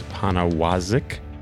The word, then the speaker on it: Poniewozik: That is me coming to you live on tape from Vox Media headquarters in New York, here with the great James Poniewozik. See how I pronounce that very slowly? Did I get it correct? – Like a pro.